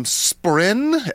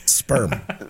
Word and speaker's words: spren: But one of the things he talks about a lot is he calls them spren, S-P-R-E-N. – Sperm. – So that's where he probably got it from. – You're mispronouncing it.